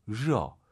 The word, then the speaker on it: 热: 러안 된다니까요. 러 혀를 좀 말아서 천장에 대면서 드럭 러.